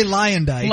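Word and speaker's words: Luyendyk: She was on the Bachelor season competing for the heart of Ari Lewandyke. – Ari Lyandyke. – Lyandyke. – It was Ari Lyandyke Jr. I've never known how to say it.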